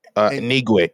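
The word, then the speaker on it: Anigwe: Uh, how do you pronounce it? Ngwe? Ngwe? – Enigwe, Enigwe, Enigwe, Enigwe, Kristine Enigwe.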